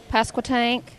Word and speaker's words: Pasquotank: Ahoski, Pasquotank.